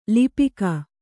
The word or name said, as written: ಲಿಪಿಕ